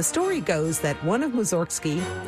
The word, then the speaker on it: Mussorgsky: In Russian, his name is pronounced Mussorgsky, which sounds like it has something to do with cows, but it doesn't.